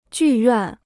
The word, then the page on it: 剧院: jù yuàn